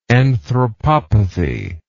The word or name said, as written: anthropopathic